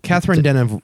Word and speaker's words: Catherine Deneuve: Catherine Deneuve.